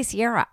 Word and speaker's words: Ciara: By the way, we say Ciara, right? – Yeah, Ciara.